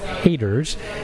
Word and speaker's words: H8RZ: I say that because the title is actually spelled H-8-R-Z, but pronounced Haters.